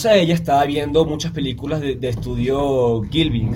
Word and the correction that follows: Ghibli: creo que se dice así. Ghibli. – Ghibli, ajá.